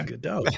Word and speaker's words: Godot: Yeah, Godot.